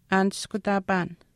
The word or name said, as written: aaniskutaapaan